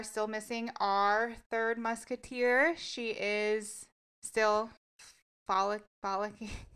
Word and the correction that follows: frolicking: I'm never going to say this word. – Follicles? – You know the follicles. How do you say the word for me? – Frolicking? – Frolicking. Yeah. You're going to hear the... Frolicking through Scotland.